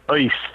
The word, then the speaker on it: Uist: And I heard about an island off the northwest Scottish coast called South East, UIST, it's pronounced, one of those little islands.